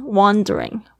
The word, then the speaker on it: wandering: 它的这个呃，这个 A 的发音是发那个好像倒 C 那个啊。 are the same one wandering wandering OK aimlessly 这个 aimlessly aim 是一个目标，less 就是没有目标。